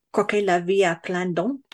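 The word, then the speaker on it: croquer la vie à pleines dents: like croquer la vie à plein dents. – Oh, joli. Yeah. Very good. – Did I say it right? – Yeah, you did.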